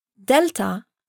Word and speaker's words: delta: By the same token, delta, delta, does not sound like D. It sounds like the TH in that.